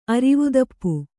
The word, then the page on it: ಅರಿವುದಪ್ಪು: aivudappu